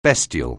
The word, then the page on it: bestial: anglais